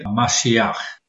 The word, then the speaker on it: Mashiach: The Hebrew is Mashiach. English we say Messiah, but the word is translated into Greek as Christos.